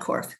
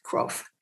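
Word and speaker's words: Kropf: And in writing the book, I had the privilege of connecting literally by email and text with Reverend Marlene Korff, who wrote the last book that's been published by Group Spiritual Direction before this one. I actually think I just mispronounced her name. It's K-R-O-P-F, not Korff, Krof.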